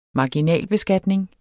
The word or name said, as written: marginalbeskatning